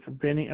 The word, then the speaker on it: Benny: It's Benny Elmore. – Can you spell that for accuracy? – B-E-N-N-Y. – B-E-N-N-Y.